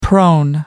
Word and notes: prone: American English

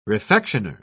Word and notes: refectioner: British English

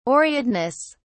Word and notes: aureateness: British English

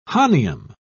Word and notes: hahnium: British English

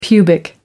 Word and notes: pubic: American English

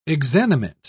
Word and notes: exanimate: British English

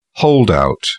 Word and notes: holdout: British English

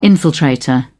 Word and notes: infiltrator: British English

